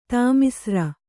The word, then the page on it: ತಾಮಿಸ್ರ: tāmisra